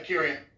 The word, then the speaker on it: Akurian: And now that I Akurian, is that how you pronounce it? – Yeah, Akurian.